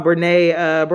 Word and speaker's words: Brené: I think her name is Brené. I think that's how you pronounce her name. – Talking about Brené Brown, right? – Yes.